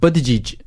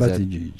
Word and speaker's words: Buttigieg: Pete Buttigieg ya da Buttigieg, tam nasıl okunduğunda bilemiyoruz, telaffuz edildiğini, seçimden çekildi. – Ben dinledim, kendisi şey yaptı. Buttigieg diyor Buttigieg.